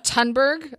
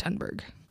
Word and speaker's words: Thunberg: I think it's pronounced Thunberg, but it's with a TH. Thunberg, Greta Thunberg.